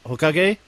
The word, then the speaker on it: Hokage: Hokage.